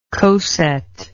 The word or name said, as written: coset